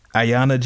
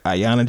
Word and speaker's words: Ayanna: Ariana? How you pronounce this, bro? I just literally said. Ariana Jackson, I think her name is. How do you pronounce her name? Ayana Jackson. All right, Ayanna Jackson.